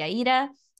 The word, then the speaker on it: Aida: And yeah, for anyone who's new to me and my world, my name is Kelsey Aida. Like the Italian opera, I always say it's actually my middle name, not my last name.